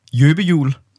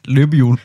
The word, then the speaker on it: løbehjul: Jøbehjul. Altså løbehjul, men det bliver sagt som jøbehjul.